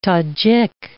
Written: Tajik

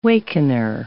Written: wakener

